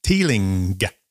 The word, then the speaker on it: Teeling: And we're going to try the Teelin Small Batch Irish whiskey. – Yeah, we are. – Have I pronounced that right? – Teeling. Yes.